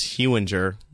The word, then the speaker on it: Hewinger: I often get Hoinger. – Ooh. – Or Hunger. – Hunger a lot. Or I also get Hewinger. – Hewinger. – Soft G. Soft G. – It's a soft G.